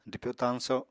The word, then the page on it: dwpyo-tañso: apatani